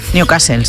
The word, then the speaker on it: Newcastle: Continúa el goteo de focos de la enfermedad de Newcastle en, lo he dicho bien. – Newcastle, sí.